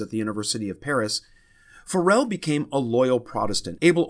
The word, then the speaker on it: Farel: Now, some pronounce that Farrell, but we're going to go with a more traditional Farel.